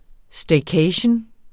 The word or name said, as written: staycation